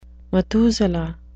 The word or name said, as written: methuselah